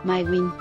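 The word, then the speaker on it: Maewyn: St. Patrick's Day could have been St. Marwan's Day? I got to look for a pronunciation on this.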